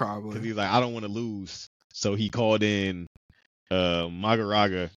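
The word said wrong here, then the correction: Mahoraga: I feel like I'm saying that wrong. – I think it's Maharaga. – Maharaga. – It's M-A-H-O-R-A-G-A. Yeah, so he called out Maharaga, and he was like, all right, we're going to have to do this ritual together.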